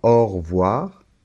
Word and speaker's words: au revoir: Une dernière chose que je voulais te dire par rapport à la prononciation, c'est que la lettre E de revoir, cette lettre E, quand on parle un peu comme ça rapidement, au revoir, on ne l'entend pas.